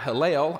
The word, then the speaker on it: Hillel: And then the other rabbi, his name is Rabbi Hillel. I am confident I'm pronouncing that one right, because I looked it up.